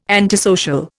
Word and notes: antisocial: Indian

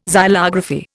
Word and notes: xylography: Indian

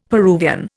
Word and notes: Peruvian: Indian

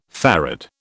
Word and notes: farad: British English

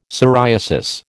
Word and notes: psoriasis: American English

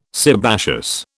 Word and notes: sebaceous: British English

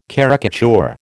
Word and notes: caricature: American English

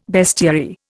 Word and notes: bestiary: Indian

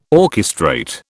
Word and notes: orchestrate: British English

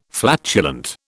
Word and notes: flatulent: British English

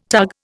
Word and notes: tug: Indian

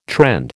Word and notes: trend: American English

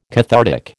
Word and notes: cathartic: American English

